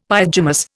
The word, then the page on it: pyjamas: Indian